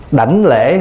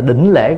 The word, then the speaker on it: đảnh lễ: Đảnh lễ hay là đỉnh lễ cũng được. Người Bắc phát âm là đỉnh lễ: nhất tâm đỉnh lễ. Đảnh đỉnh thì cũng một chữ đó.